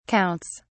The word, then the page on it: counts: British English